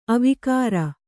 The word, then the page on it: ಅವಿಕಾರಿ: kannada